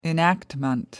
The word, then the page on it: enactment: British English